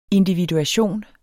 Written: individuation

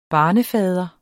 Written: barnefader

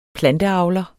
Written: planteavler

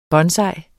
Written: bonsai

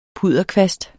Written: pudderkvast

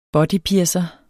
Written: bodypiercer

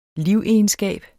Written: livegenskab

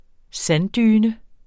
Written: sanddyne

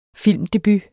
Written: filmdebut

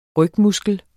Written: rygmuskel

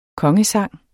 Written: kongesang

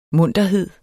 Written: munterhed

